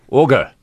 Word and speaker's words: Augur: A-U-G-U-R. – Augur. – Yeah. – Isn't it pronounced Augur? – Yeah.